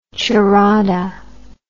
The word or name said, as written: charrada